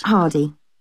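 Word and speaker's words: Hardie: Hardy. – Can you spell that, please? – Yes, it's H-A-R-D-I-E.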